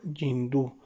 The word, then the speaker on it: hindú: en hindú hindú pronunciado yindú.